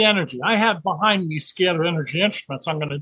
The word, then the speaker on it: scalar: scalar energy. – Wow. So I've been saying it scholars, I've been saying it wrong, it's scalar energy.